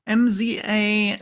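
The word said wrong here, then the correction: Mzali: Mazali. – Mazali. Yeah, okay. – That's the city where the Bright Lions operate in the Mwangi Expanse. – Right. I can never pronounce it. There's like a hidden vowel there.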